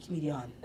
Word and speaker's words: Comedienne: And then I have a fan page too on Facebook, and I think that's the French way, Comedienne, still C-O-M-E-D-I-E-N-N-E, Comedienne.